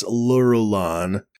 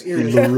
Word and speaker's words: Irulan: Unless that's Lurulan. – No, it's Irulan.